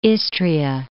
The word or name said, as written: Istria